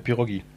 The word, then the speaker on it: Pirogi: Ja, Pirogi. Nee, aber mit E hin. Nee, ist aber wie ein I ausgesprochen.